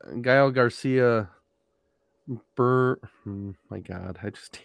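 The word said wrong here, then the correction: Gael García Bernal: Gael Garcia Bernal.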